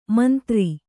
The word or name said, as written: ಮಂದುರೆ